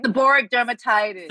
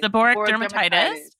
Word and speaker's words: seborrheic dermatitis: Seborrheic dermatitis. How do you say that? – Seborrheic dermatitis. – Seborrheic dermatitis. Yes. As an adult.